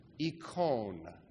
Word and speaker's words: eikon: In Greek, the word is simply pronounced ikon. Ikon, for the word image. Ikon is spelled E-I-K-O-N. Many of you will begin to recognize it now, E-I-K-O-N, as the English word icon.